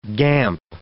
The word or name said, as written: guimpe